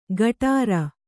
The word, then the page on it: ಗಟಾರ: gaṭāra; Kannada